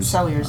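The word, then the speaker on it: Celliers: But still that character that sort of observes the weirdo, which is Sellier, or Selliers. – Selliers. – Selliers. I want to pronounce it like a French name.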